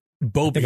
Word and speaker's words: Boebert: The other, her counterpart, the other member of the conservative squad, Laura Boebert. – Boebert, yes.